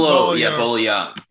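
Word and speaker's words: Bolo Yeung: Just watch Jean-Claude Van Damme and Bola Young? How do you pronounce his name? – Bola Young. Yeah.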